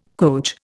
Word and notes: coach: Indian

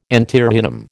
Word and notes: antirrhinum: American English

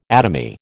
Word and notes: atomy: American English

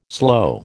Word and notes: sloe: American English

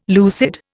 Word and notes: lucid: Indian

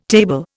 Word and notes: table: Indian

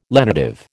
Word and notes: lenitive: American English

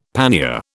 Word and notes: pannier: British English

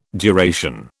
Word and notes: duration: British English

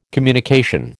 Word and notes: communication: American English

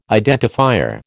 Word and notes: identifier: American English

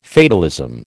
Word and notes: fatalism: American English

